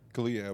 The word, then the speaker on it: Kaluuya: how do I say his last name? – Kalia, I believe. There we go.